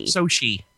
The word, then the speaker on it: Sochi: Sochi. – Sochi. – Is that how you say it? Sochi? Sochi, Russia? – Yeah.